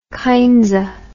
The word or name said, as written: kindsa